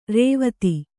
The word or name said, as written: ರಾವ್ತ